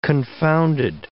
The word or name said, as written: confounded